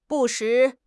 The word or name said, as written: 不时